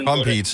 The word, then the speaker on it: Compeed: Compete, C-O-M-P-E-E-D.